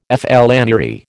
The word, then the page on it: flânerie: American English